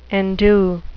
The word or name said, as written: endue